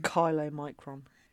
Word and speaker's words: chylomicron: How would you say that word? – Silomicron. – I was going to go with... – Silomicron. – Chylomicron. – Chylomicron. You're right, you're right. You are so right. Chylo, yeah. Okay. – Chylo? – Chylo. – Like if you think like CH.